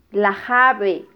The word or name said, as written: lajabe